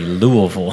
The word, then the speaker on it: Louisville: That's how you say it correctly, Louisville, for all you non-Kentuckians out there.